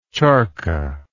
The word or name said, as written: charkha